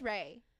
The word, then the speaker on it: Rae: My middle name is Ray. – Is it spelled with an E? – R-A-E, yeah.